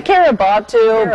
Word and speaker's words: Kiribati: Researchers think she landed on a part of the Phoenix Islands chain in the Pacific Nation of Kiribati. Did I say that right? – Well, Kiribati, but I've heard, but I like Butu.